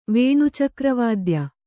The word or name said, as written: ವೇಣುಚಕ್ರವಾದ್ಯ